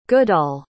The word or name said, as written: goodall